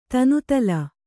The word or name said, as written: ತನುತಲ